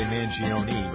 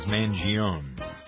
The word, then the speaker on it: Mangione: This is the 70th birthday for Chuck Mangione. Some people say Mangione, but the correct Italian pronunciation, Roberta, correct me if I'm wrong, is Mangione.